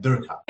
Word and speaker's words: Durka: I mean, in typical Nepali uh, pronunciation, it's Chirpi and Durka.